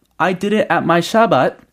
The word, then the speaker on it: Shabbat: 근데 여기서는 Shabbat라고 단어가 발음이 되었어요.